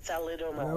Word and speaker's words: thalidomide: How do you say it? Formaldehyde? What? Formaldehyde? – Thalidomide. – Yes. – Thalidomide.